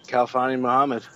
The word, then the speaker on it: Kalfani Muhammad: overall, we took, and I know I'll say this wrong, but Kalfani Muhammad, running back out of Cal. What do you think about this guy? – I think you said that right, Kalfani Muhammad.